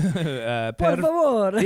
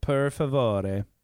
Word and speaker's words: per favore: Per favore. – Yeah, you got it. – I just said the Spanish word. – Per favore. – How do you say it? – Per favore.